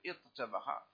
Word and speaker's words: اطبخ: اطبخ، اذا تقول اطبخ ثم تقلب التاء طاء، تقلب التاء طاء ثم تضغم الطاء في الطاء وتقول اطبخ، اطبخ.